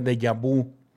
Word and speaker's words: déjà vu: Se escribe deja o deja vu. De con acento en la E, ja con acento en la A, deja vu. Se pronuncia déjà vu.